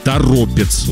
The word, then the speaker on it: Торопец: Город Торопец». Ударение на «о» второе здесь поставлено. Ну, все правильно прочитал.